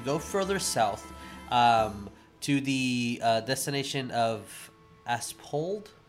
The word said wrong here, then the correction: Asphodel: How do you pronounce that? – Asphodel. – Ash- Asphodel. Oh, Asphodel. Yeah.